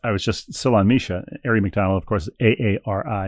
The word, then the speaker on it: Misia: Misha, who helpfully includes a pronunciation guide, is our next correspondent. Her name is spelled M-I-S-I-A, but she parenthetically writes Misha.